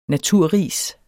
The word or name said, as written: NATURRIS